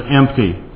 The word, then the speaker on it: empty: If you listen carefully to how kids are pronouncing them, they're often not getting the T in there. Their glass is empty. You know, they want to get there promptly with no T in it.